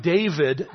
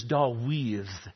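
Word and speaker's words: Dawid (דוד): We pronounce his name David. That is not how it's pronounced in Hebrew. It is Dawid.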